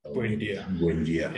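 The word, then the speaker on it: Buendía: Buendia.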